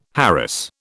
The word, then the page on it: harass: British English